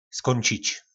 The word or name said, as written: skoncic